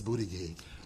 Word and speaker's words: Buttigieg: I thought it was Buttigieg. – So I watched him pronounce it. He says Buttigieg.